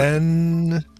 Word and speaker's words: Ben-Gurion: Ben-Gurion.